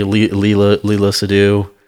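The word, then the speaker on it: Léa Seydoux: So Margaret Qualley played in that, you know, like played in the movie, Leela Seydoux. – Yeah. – Am I pronouncing that one right? – And that one is right.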